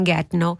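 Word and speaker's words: Gettnau: E non sapendo l'inglese, lei sentiva i Rolling Stone che cantavano I Can Get No. Lei era cresciuta a Get Now, vicino a Villisau, canton Lucerna, che però appunto Get Now si pronuncia in dialetto del luogo Getno, e quindi lei credeva che gli Stones cantassero del suo paese.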